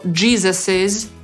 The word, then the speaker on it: Jesus's: Tak samo na przykład but Jezusa to Jesus's shoe. Mimo że dodajemy to S w wymowie, to zapisujemy tylko dodatkowy apostrof.